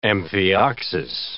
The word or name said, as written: amphioxus